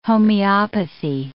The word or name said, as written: homeopathy